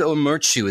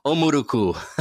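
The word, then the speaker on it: Ó Murchú: Hi, I'm looking for Diarmaid O'Murcu. Is he available? – Yes, this is Diarmaid O'Murcu. Thank you. – Oh, man, I said your last name wrong, didn't I? – O'Murcu. Yeah, that'll pass. – O'Murcu.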